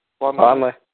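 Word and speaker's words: Vonleh: Von Lay, free agent. Is that how you pronounce that? – Von Lay. Yeah.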